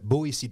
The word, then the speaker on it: Boétie: B-O-E-T-I-E. Boétie. De la Boétie. – Boétie, OK. – Boétie, OK. Bon, mais c'est pas des S, là, mais c'est un T. Ça se prononce Boétie.